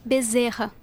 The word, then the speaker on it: Bezerra: D-A-R-L-Y. – D-A-R-L-Y? – Yes, that's right. – And what's your surname? – Bezerra. – Bezerra. Is that B-E-Z-E-R-A? – B-E-Z-E-R-R-A. – B-E-Z-E-R-R-A.